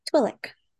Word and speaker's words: Twi'lek: Jabba figures everything out and kills the Twi'lek and Why are you saying it like that? – how else do you pronounce it? – Twi'lek.